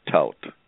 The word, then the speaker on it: Thaut: T-H-A-U-T, silent H, Taut.